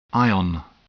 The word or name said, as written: ion